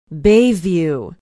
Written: bayview

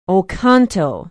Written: oconto

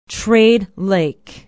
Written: trade lake

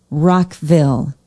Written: rockville

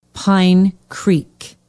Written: pine creek

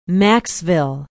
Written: maxville

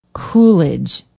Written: Coolidge

